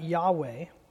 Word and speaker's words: Yahweh: Today we pronounce that Yahweh.